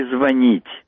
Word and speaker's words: звонит: Теперь много всяких разговоров идет по поводу слов «включить» и «звонить». Как правильно ставить ударение в формах настоящего времени? В их словаре было неправильно «включит» и не рекомендуется «звонит». Это запретительные пометы были. Мы «звонит» тоже не допускаем.